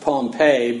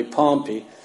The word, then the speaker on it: Pompey: or you may pronounce it Pompey, but it's really Pompey.